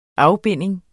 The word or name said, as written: afbinding